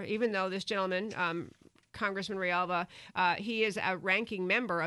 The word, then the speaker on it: Grijalva: His name is Raul Rialva, and his last name is actually spelled G-R-I-J-A-L-V-A, but I looked it up. It's pronounced Rialva.